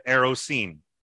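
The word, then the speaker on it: erusin: So we're at the day of the erosin. This one, I actually know I'm pronouncing it right because I had a dictionary and I looked it up.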